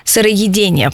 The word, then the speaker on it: сыроедение: Еще раз напомню тем слушателям, которые слышат нас впервые, практика сыроедения – это сыроедение, правильно, да, ударение.